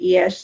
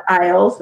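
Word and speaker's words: Illyes: I don't like in SEO how everybody freaks out about like what Gary Iesh says. And I know that's how you pronounce his name because I asked him. not Isles, that's what everyone thinks it is.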